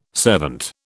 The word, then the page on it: servant: British English